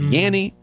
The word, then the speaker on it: Yanni: It's Yanni, which rhymes with nanny, and Laurel, which is, you know, a name.